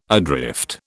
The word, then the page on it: adrift: British English